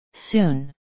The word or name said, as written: soon